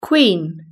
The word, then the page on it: queen: British English